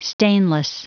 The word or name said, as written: stainless